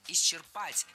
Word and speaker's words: исчерпать: Раньше нормативным был вариант исчерпать. Только он один, никаких вариантов. Потом появилась двойная норма. Исчерпать и исчерпать. Но по последним сведениям, нормативным считается только вариант исчерпать. Те, кто говорит иначе, пора переучиваться на исчерпать.